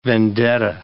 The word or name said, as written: vendetta